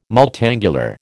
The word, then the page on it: multangular: American English